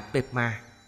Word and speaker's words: Padma: Từ Padma trong tiếng Sanskrit cũng được dùng trong tiếng Tây Tạng và phát âm là Padma, có nghĩa là hoa sen.